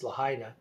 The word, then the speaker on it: Lahaina: I don't know why I felt like pronouncing it like that, Lahaina, but apparently the pronunciation is Lahaina.